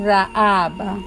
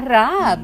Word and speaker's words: Rahab: Aquí tenemos hoy a Rahab, que también se escribe con H o con J o Rahab. Así que si se pronunciara en español, como la H es silente, sería Rahab, pero con H.